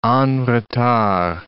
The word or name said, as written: en retard